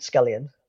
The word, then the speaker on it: Scullion: At Pete Scullion. It's pretty straightforward. I never went for one of these slightly strange Instagram handles. It's just my name. Sounds like it's spelled.